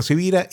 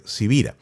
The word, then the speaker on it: Sivira: Mi nombre es Carlos Javier Sivira y bueno, estoy claro que mi apellido no es tan común, así que se escribe con S de semana y con V de Victoria.